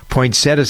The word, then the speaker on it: poinsettia: Do you know how to pronounce that? Poinsettia, some people pronounce it, but it's poinsettia. That's the way the dictionary tells us. – You have to get that extra syllable in there. Right, exactly. Yeah, there's a T-I-A in there, poinsettia.